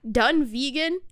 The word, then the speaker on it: Dunvegan: There's no way it's pronounced Dunvegan, but it's spelled Dunvegan. D-U-N-V-E-G. – Ooh, I don't know. – Dunvegan? – It's literally Dunvegan.